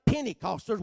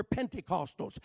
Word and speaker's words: Pentecostals: I know that people look at them, they fail to pronounce the T, they call them just Penny. Pentecostals. Well, we're not Pentecostals, we're Pentecostals. And that term Pentecost means 50.